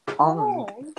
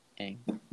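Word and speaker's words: Aang: Ong. – Can't even pronounce his name right. Ang.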